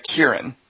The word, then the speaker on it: Ciarán: Weren't we told, Dingus, that Ciarán is actually Kiernan? Like Kieran.